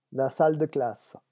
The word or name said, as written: la salle de classe